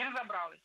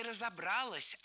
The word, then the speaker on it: разобралась: Я понимаю, что я выгляжу, ну, глупо, да, то есть я тут над собой посмеялась, говорю, ну, дурочка, слушайте, не разобралась, да? – Нет, не разобралась, а разобралась. Опять же, ударение ставим на последний слог, поближе.